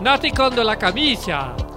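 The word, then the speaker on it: Nati con la camicia: Le titre d'origine est Natty la camica, la camicia, ya, yeah, ici, merci. Dis-le l'italien si tu le prononces. Ah si, je vais le prononcer mieux que toi. Nati con de la camicia. Euh, c'est mieux tout d'un coup. C'est mieux, c'est mieux. Oui, effectivement, c'est mieux.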